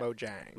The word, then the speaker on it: Mojang: Mojang.